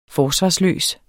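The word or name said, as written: forsvarsløs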